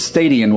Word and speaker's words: stadion: It almost sounds like the word stadium, but it's stadion with an N on the end.